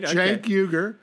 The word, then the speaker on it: Cenk Uygur: My friend Cenk Uygur, because I'm one of the three people in the world, apart from his parents, who know how to pronounce his name. – They pronounce his name right. – Cenk Uygur.